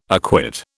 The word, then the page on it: acquit: British English